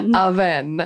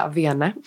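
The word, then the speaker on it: Avène: Aven. – Uh-huh. Jag tänkte säga Avene. – Mm. – Det heter det inte, det heter Aven.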